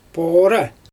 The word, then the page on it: poora: Xyzyl